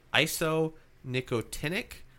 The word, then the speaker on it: isonicotinic: P-A-S. – No. – P-A-S. Okay. – P-A-C. – Yes. The next one was isonicotinic Perfect. Acid hydrazide. – Perfect. – Or I-N-H.